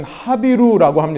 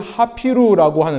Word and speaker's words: 하비루: 슬라이드를 보여주시면 하비루라고 합니다. 혹은 하피루라고 하는데요. 하비루는 이 히브리식 발음이고 하피루는 이 아랍식 발음인데요.